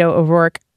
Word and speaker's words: O'Rourke: Camille, they're still trolling me on my Beto O'Rourke. – That was good. – That's I didn't good. Say it differently. – C plus. – Camille says it should rhyme with Camille says it should rhyme with O'Fork.